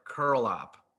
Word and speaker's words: Curelop: No, but I'd say like 5% of people actually say it right. It's Curelop.